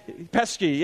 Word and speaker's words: Pesci: The one that's out right now, is it Joe Pesci? Is that how you say his name? Pesci, yeah.